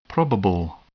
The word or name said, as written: probable